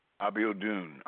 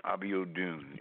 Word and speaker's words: Abiodun: Abiodun. Abiodun.